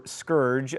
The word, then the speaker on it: scourge: Seemingly monotheistic, feels like the heathens, in this context likely the Native Americans, have been sent as a scourge, or scourge, as it is apparently properly pronounced.